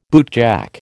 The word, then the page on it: bootjack: American English